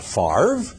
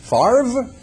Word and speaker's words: Favre: Not Favre, nonsense. F-A-V-R-E, Favre, you take the R and stick it in front of the V? No, it's Favre.